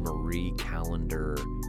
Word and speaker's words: Marie Callender: You know, my wife brought home a frozen Marie Callender. Is that how you pronounce that? – Like yeah.